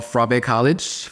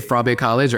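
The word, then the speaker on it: Fourah Bay College: It is called Frabe College. It's spelled F-O-U-R-A-H, Bay College. But in Sierra Leone, we pronounce things differently than how it's not phonetic. – Yes. – So they say Frabe College or FBC.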